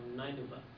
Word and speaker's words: Nineveh: How do you pronounce it? – They call it Nineveh.